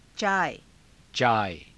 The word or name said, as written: caaya